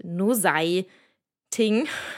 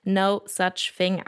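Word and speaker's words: Nosaj Thing: Äh, Achtung, der Name wird ein bisschen anders geschrieben, als man ihn ausspricht. Äh, man würde es jetzt auf Deutsch vielleicht No Sei Casting lesen. Ähm, etwas verwirrend, aber genau, spricht sich No Such Thing aus.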